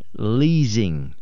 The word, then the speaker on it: leasing: I would read this word as the word leasing, but it's actually pronounced leasing. L-E-E-Z-I-N-G. That's how you would pronounce it.